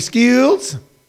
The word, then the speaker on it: Schools: We come to you live on YouTube on Comedy Skills. And by the way, that's not spelled S-K-E-W-L-S, even though that's how it sounds. It is spelled the normal way, S-C-H-O-O-L-S, schools, but we pronounce it skills.